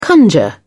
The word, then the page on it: conjure: British English